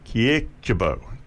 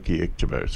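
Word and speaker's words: Kyrkjebø: Surname is K-Y-R-K-J-E-B-O with a stroke through it. Kiekjebo, Sissel Kiekjebo.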